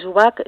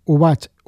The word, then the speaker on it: Ubach: Sí, mira, yo tengo, yo tengo la página web que es eh, Chris Ubac. Eh, Chris Ubac se escribe con K y acabado en CH.